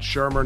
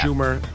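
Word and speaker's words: Shurmur: If you are a huge Giant fan, you have to know it's Pat Shermer, not yeah. – Schumer. And you have to know how to pronounce Saquon Barkley.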